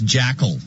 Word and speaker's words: JCL: We know about NIMBYs and even YIMBYs, but I think we should also need to start throwing around the term JCL, pronounced Jackal, which stands for Johnny or Jenny Come Lately.